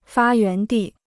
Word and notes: 发源地: fā yuán dì

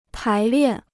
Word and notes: 排练: pái liàn

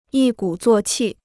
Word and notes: 一鼓作气: yī gǔ zuò qì

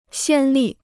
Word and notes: 先例: xiān lì

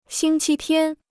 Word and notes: 星期天: xīng qī tiān

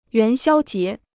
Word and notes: 元宵节: yuán xiāo jié

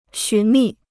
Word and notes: 寻觅: xún mì

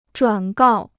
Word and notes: 转告: zhuǎn gào

